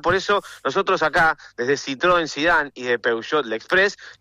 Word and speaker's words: Citroën: Citroën es Citroën. No es Citroën.